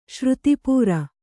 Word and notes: ಶ್ರುತಿಪೂರ: Kannada

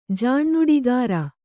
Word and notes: ಜಾಣ್ನುಡಿಗಾರ: Kannada

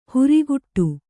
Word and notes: ಹುರಿಗುಟ್ಟು: Kannada

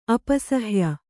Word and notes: ಅಪಸಹ್ಯ: apasahya

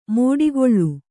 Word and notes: ಮೋಡಿಗೊಳ್ಳು: mōḍigoḷḷu

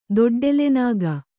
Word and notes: ದೊಡ್ಡೆಲೆನಾಗ: kannada

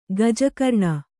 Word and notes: ಗಜಕರ್ಣಿ: kannada

